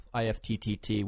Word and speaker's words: IFTTT: And so now if somebody tweets you or favorites something of yours, it now integrates with IFTTT, which is pronounced IFT. Like gift, but ift.